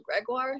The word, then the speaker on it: Gregoire: It's spelled Gregoire, so it's G-R-E-G-O-I-R-E.